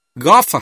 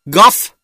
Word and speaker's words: golf: Golf à? Anh phát âm theo em nè. Golf. Không phải golf đâu nghe. Nhẹ thôi nghe.